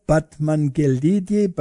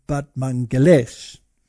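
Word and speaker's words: Batmanghelidj: Now, there was a scientist, and his name was this one over here, Batmanghelidi, but apparently you pronounce it Batmanghelesh.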